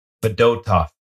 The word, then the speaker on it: Fedotov: So how do we pronounce his last name? – Fedotov is what I've been told.